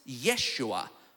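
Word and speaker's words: Yeshua (ישוע): Now, this is interesting because the name Joshua in Hebrew is pronounced Yeshua, or in Greek, Jesus.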